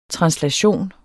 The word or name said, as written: translation